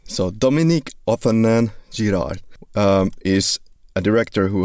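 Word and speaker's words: Dominique Othenin-Girard: Uh, my friend who was thinking of being part of this podcast before, he gave me an audio recording of how to pronounce the goddamn director's name. So it's Dominique Othenin Girard. There you go.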